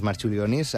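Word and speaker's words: Marčiulionis: Jo crec que li diuen Marciulionis, però... Perquè aquesta C es pronuncia com una TX.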